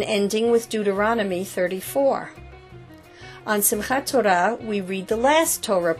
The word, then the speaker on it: Simchat Torah: Simchat Torah, Simchas Torah in the Yiddish pronunciation, means rejoicing in the Torah.